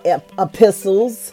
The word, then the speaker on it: epistles: Now, these are the pastoral epistles, and it is this word, the pastoral epistles, or this title, the pastoral epistles, or epistles, is the desert because the T is silent.